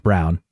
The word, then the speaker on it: Braun: Even though it looks like Braun, it's pronounced Brown.